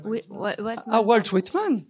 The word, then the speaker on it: Whitman: Wittmann. – C'est qui? – C'est un auteur, enfin je le prononce peut-être mal. – Walt Whitman. Walt Whitman.